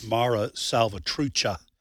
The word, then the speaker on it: Mara Salvatrucha: By the way, I mispronounced the name. It's Mara Salvatrucha.